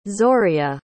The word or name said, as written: zorea